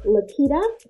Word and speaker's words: Laketta: And on YouTube, it's Confidence on Stilettos with Lakita. My name is spelled L-A-K-E-T-T-A.